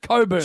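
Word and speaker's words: Cockburn: No, it's pronounced Coburn. – Jim, how do you spell it? That's a question. – No. – C-O-C-K.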